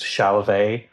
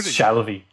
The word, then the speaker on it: Shalvey: And I heard that Eric Stevenson, the publisher of Image Comics, thought my second name was pronounced Shalvey, which is a much fancier way to pronounce it. – Right, yeah. – That's just Shalvey, like in the bog.